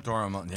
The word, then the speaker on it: Dora Milaje: I think that you had within the Dora Milaje, is that how you pronounce that? – Dora Milaje.